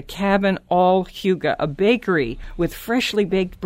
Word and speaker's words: hygge: Here we have the authors of the Huga Life, and it's spelled H Y G G E.